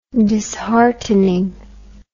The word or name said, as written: disheartening